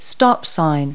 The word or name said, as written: stop sign